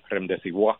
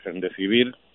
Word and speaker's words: Remdesivir: que todavía Trump no ha aprendido a pronunciarlo, estaba diciendo el otro día Rendecibois como si fuera una droga francesa.